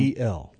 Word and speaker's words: El: So it's E-L. – E-L. Okay. El, right. Or L, we might pronounce it sometimes.